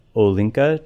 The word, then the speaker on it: Olinka: It's just all of these letters of Nettie's experiences in Africa with the Olinka tribe.